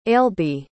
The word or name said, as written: ailbe